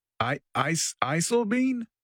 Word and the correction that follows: Eisleben: I mean. – Eisleben.